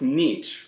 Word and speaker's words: niche: I realized that there are some people out there that they, like us, we all have some kind of niche, right? Is that the right way to say it? Niche? – Yep. – Niche? – No, that's right.